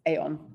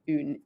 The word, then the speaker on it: Aon: So this is, so all of our single casks are called Oon, which is spelled A-O-N. So I initially pronounced this Aeon, but you have to think of a Scottish person saying Oon in French, but with a Scottish accent, Oon. So that means one, obviously, in Gaelic.